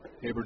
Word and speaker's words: Haberer: And the last name is H-A-B-E-R-E-R, but the second E-R is silent. Called strike outside corner 0-1, if that made sense. It's Haber. Looks like Haberer.